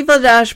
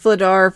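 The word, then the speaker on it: Vladař: It should be Vladash, but it's pronounced Vladar because Canada.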